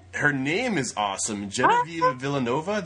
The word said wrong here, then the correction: Villamora: Did I say that correctly? – Yeah. Villamora.